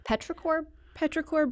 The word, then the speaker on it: Petrichor: Petrichor Brewing, you did it.